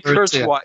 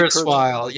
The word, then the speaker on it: Kurzweil: I shy away from Curse, I don't know how to pronounce his name. – Well, it's Ray Kurzweil. Yeah, it's Ray Kurzweil.